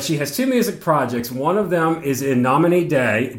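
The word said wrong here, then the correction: In Nomine Dei: Did I pronounce that right, Alina? – Almost right. Yes. – Almost. Okay. – That was gracious. – That's a universal language out there. So how do I pronounce that without sounding like a Southerner in the United States? – From Latin, so you shall pronounce it in Nomina Dei.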